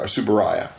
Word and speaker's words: Tsuburaya: Hajime Tsuburaya is our Tsuburaya. I keep pronouncing it incorrectly, and I have to remember it is rye like the beverage.